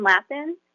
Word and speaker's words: Lappin: Hi, this is Lauren Lappin, L-O-R-I-N-L-A-P-P-I-N.